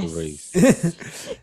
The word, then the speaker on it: Nyck de Vries: Is it Nick DeVries? – Oh, yes. Nick DeVries.